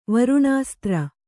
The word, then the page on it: ವರುಣಾಸ್ತ್ರ: Kannada